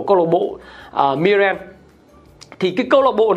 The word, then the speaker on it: Mirren: Mirren đọc là Mirren nữa